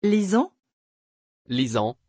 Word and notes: Lysan: French

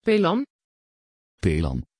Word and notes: phelan: Dutch

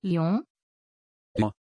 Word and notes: Lyon: German

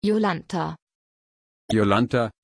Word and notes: Yolantha: German

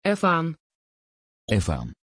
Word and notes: évan: Dutch

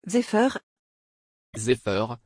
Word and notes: Zephyr: French

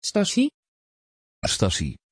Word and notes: Stacie: Dutch

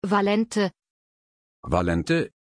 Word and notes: valente: German

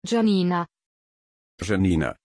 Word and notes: giannina: German

